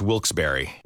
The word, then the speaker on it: Wilkes-Barre: Now, I had heard that it was supposed to be pronounced Wilkes-Barre.